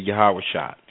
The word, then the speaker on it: Yahawashah: You're going to hear me say Yahawashah. That's the way I believe it's correctly pronounced, using what is called ancient Hebrew.